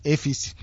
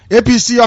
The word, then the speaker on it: APC: Another APC or AFC as the Hausa person will pronounce it. You know, they don't have P. It's F, AFC. APC office bombed, 5 youths die in Abua, Odua.